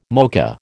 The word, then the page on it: mocha: American English